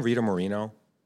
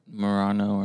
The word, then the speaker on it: Moreno: Wait, is her name Rita Moreno? Hold on. – Morano or Morano. – No, I think you said it correctly, actually.